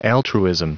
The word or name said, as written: altruism